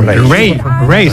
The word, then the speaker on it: Raye: Ray. – Se escribe Raye.